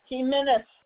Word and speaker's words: Jimenez: Jimenez. – Jimenez. – Can you spell it for me? Your last name. – J-I-M-E-N-E-Z.